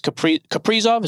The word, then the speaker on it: Kaprizov: Actually, let me ask you a question then about this Kaprizov, is that how you say it? – Yeah.